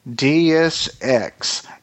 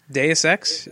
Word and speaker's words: Deus Ex: Deus Ex: Human Revolution. – Deus Ex, yeah. – Deus Ex.